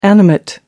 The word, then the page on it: animate: American English